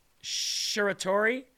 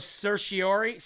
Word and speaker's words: certiorari: Last week, Smith submitted a petition to the Supreme Court for writ of, I never say this right, certiorari. I never say that word right. Writ, no, it's a stupid legal term. It's a writ of certiorari. Certiorari, it's called.